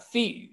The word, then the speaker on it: Fead: Feed, uh, F E A D, right? – F E A D, pronounced F E E D, but yeah, feed.